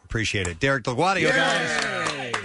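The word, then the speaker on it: Delgaudio: His last name is DelGuadio.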